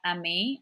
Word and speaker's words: Amiee: So my handle is at Dr. Amiee, and I think you're going to link it because my name is spelled A M I E E.